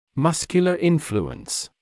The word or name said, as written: muscular influence